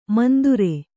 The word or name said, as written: ಮಂದುರೆ